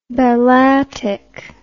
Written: balatik

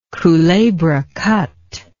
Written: culebra cut

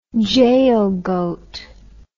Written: jaal goat

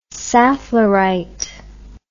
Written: safflorite

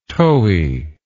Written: towy

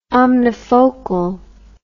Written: omnifocal

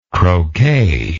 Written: croquet

